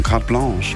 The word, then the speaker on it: Carte Blanche: Es wird Carte Blanche geschrieben und ich war so, wie spricht man das aus? Ja, es sind Franzosen. Carte Blanche.